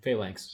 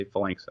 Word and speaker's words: phalanx: a phalanx formation phalanx so phalanx formation. That's how you pronounce it. I always say phalanx. I've been mm-hmm. – pronouncing it wrong my entire life. – You put um, the emphasis on the wrong syllable.